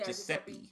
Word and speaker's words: Giuseppe: Yeah, Giuseppe.